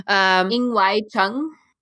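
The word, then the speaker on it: Ng Wai Chung: Ing-Wai-Chung. – Ing? – Ing. – Okay, NG is just Ing.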